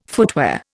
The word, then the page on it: footwear: Indian